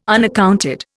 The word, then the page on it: unaccounted: Indian